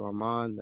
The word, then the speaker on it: Armand: My name is Matthew Armand.